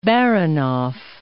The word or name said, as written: Baranof